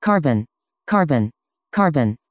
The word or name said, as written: carbon